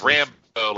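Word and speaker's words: Rambeau: Rebinoe, I believe is her name. I can't pronounce it. – Captain Marvel. – It's Rambo, like First Blood.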